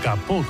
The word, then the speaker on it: capot: El alemán tomó prestado ese kaputt en el siglo XVII del francés kaputt, hoy pronunciado capot concretamente,